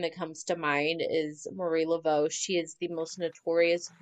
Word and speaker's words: Marie Laveau: So are you going to tell me about Marie Laveau? Am I saying that right? – Yeah, you nailed it. I am going to tell you about Marie Laveau.